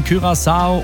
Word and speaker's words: Curaçao: Deutschland spielt am Sonntag gegen Curaçao. Und ja, das wird so ausgesprochen, wusste ich vorher auch nicht.